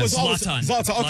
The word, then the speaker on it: Zlatan: Help me out, Chris. Zoltan. – Yeah, I used to call him Zoltan. – Zlatan. Okay. Zlatan.